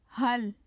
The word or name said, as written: hal